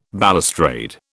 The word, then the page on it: balustrade: British English